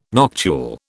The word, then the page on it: noctule: British English